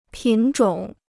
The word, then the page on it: 品种: pǐn zhǒng